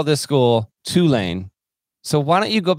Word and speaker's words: Tulane: Tulane.